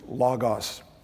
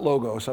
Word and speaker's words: logos: So, the term word that is used here is a common Greek word known as logos, not logos.